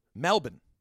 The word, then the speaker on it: Melbourne: Melbourne. – You got to say it right.